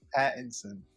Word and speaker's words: Pattinson: I thought it was weird at first, especially seeing Robert Patterson, right? That's how you say his name? – Pattinson.